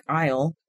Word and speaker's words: aisle: Right, or like aisle. The yeah. word aisle. Like, right. What's that A doing there?